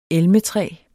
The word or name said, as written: elmetræ